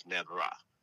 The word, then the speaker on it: Nebra: Oh, Nebra is the Kemetic Egyptian form of noble. The word noble is pronounced in the Egyptian language as Nebra, and it means Neb means Lord or the King of Metals.